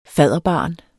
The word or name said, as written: fadderbarn